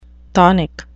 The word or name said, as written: chthonic